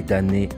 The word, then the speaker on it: damné: Et puisque l'on y souffre atrocement, qu'on est maudit, voici les damnés rejetés. Ce sont ceux qui sont exclus, les damnés, les communards. Sont-ils mal aimés? On aime, on n'aime pas. Le M dans damné ne se prononce pas.